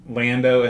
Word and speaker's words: Lando: You have weird ways of pronouncing these names. Londo, Leia. How do you pronounce it? Lando and Leia, like they're supposed to be.